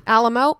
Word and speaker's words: Alamo: Am I pronouncing Alamo? – Alamo. What? Oh, why did I add an N in there? – It's okay.